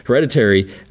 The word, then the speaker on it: Hereditary: He did Heredity, Hereditary. Um, I, I, always say it wrong. Hereditary, Midsommar, and now this one.